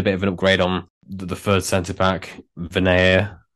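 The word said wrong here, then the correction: Vyner: That might be how you pronounce it. – Viner. – Viner.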